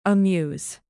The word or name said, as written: amuse